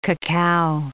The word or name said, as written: cacao